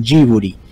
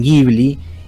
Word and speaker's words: Ghibli: y los japoneses le dicen jiburi, y los italianos le dicen Ghibli y los eh, norteamericanos, en los que, o mejor dicho, los de habla inglesa, eh, le dicen eh, Ghibli.